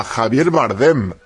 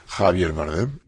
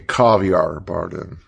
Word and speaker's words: Javier Bardem: Javier Bardem, how to pronounce? Ah, Javier Bardem. Javier Bardem. They call me everything you can imagine. Some people call me Caviar Bardem.